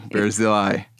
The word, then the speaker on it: Barzillai: Oh, Barzillai. – Barzillai.